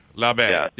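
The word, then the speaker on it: Labbé: Labbe. Yeah, there's a little thing, accent aigu at the end of the E. So there you go.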